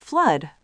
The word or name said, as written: flood